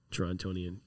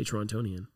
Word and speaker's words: Torontonian: Torontonian. Even that is, you shouldn't say Torontonian. It's Torontonian. Torontonian. We don't pronounce the T.